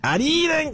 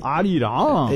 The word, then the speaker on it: Arirang: Tu tem que puxar i. Arirang. – Arirang. – Isso. – Arirang.